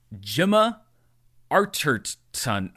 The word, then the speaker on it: Gemma Arterton: Gemma Archer. G- Gemma Archer. Gemma Archer. Okay, I'm going to get this one of these days. Gemma Arterton.